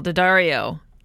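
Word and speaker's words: D'Addario: Today, brothers Brian and Michael Daddario. Did I say that right, you guys? – Yeah.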